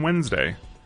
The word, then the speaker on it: Wednesday: Most Americans don't pronounce the first D in Wednesday, but there it is, sitting pretty.